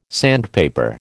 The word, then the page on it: sandpaper: American English